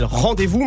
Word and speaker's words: RNDZ-VS: Et attention, le groupe s'appelle Rendez-vous, mais ça s'écrit RNDZ-VS.